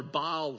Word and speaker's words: Baal: It says, and Ahab told Jezebel all that Elijah had done, and withal how he had slain all the prophets of Baal, or Baal, some would pronounce it, with the sword.